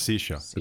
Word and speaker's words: Saoirse: It's Saoirse. It's pronounced Saoirse. – Oh, Saoirse.